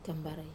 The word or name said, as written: kambarai